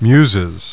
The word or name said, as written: Muses